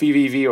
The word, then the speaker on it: VVV: I don't know if that's pronounced VVV or triple V, um, but okay.